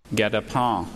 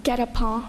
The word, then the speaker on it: guet-apens: Get a pen. – Guet-apens. Is this French? – It's from French. – Guet-apens. G-U-E-T-A-P-E-N.